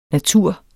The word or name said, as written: natur